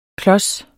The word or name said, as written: klods